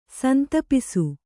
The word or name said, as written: ಸಂತಾಪಿಸು